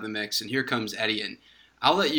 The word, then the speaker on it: Etienne: Travis Etienne, am I pronouncing that correctly? – Etienne, yeah, I think so.